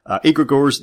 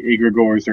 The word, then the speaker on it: egregores: And I'll say egregores or egregores and kind of slide back and forth. So we're all saying it improperly. And I've been told the first G is silent, as in gnome.